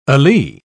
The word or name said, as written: alee